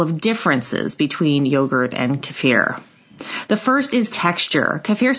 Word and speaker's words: kefir: Both yogurt and kefir, which you'll also hear pronounced kefir, are cultured milks that thicken when beneficial bacteria consume the sugars in the milk and acidulate it.